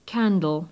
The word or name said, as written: candle